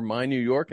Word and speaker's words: MI New York: MI New York, or My New York, as they prefer it to be called phonetically, chose Steven Taylor with the third overall pick.